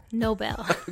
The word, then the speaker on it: Nobel: And it rhymes with Bobel. – Nobel. Okay. Okay.